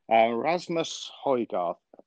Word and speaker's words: Rasmus Højgaard: There's a young man on the European tour, Rasmus Højgaard, I hope I've said it right. – Very good. Very good.